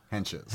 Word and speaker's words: Hentges: Hentges.